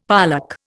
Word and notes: pollack: Indian

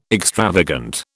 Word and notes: extravagant: British English

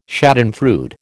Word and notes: schadenfreude: American English

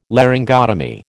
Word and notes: Laryngotomy: American English

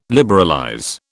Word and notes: liberalize: British English

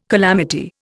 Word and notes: calamity: Indian

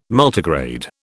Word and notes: Multigrade: British English

